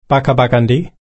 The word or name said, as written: bakkabakkandi